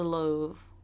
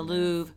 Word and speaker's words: Louvre: And then loved the Louvre. I'm saying that wrong. The Louvre. Uh-huh.